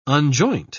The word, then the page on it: unjoint: British English